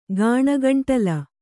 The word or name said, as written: ಗಾಣಗಂಟಲ